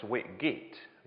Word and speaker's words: Whitgift: I think his name's spelt Whitgift, but I think it's pronounced Whitgit, which is...